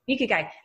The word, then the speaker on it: Ikigai: how to pronounce it, the Ikigai, right? Which is Ikigai.